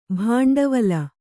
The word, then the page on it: ಭಾಂಡವಲ: bhāṇḍavala